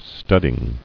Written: Studding

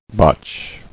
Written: Botch

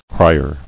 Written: Crier

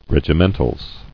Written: Regimentals

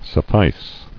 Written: Sufficed

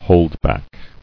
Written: Holdback

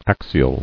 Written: Axial

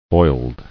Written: Oiled

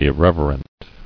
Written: Irreverent

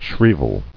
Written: Shrievalty

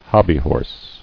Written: Hobbyhorse

